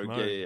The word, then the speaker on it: Moog: It's pronounced Moog. Yeah. But MOOG.